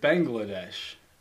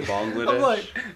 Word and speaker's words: Bangladesh: How do you say that? – I said it Bangladesh. – Oh, like bang bang. – Bang. Yeah, bang. Like okay, it's spelled B A N G.